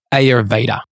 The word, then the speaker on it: Ayurveda: And again, keep coming back to Ayurveda because, and by the way, we get pulled up on how we pronounce Ayurveda. We know how to pronounce it. It's actually Ayurved. – Yeah, because they don't say, they don't say ours. – We know how to say it, but it feels like. – Whatever. However you want to say it, just say it.